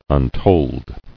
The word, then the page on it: Untold: un·told